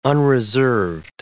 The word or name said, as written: unreserved